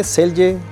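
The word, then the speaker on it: Celje: ¿Selje? – Selje.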